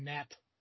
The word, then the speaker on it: gnat: I bet they also probably pronounce the G when they you know say the word gnat.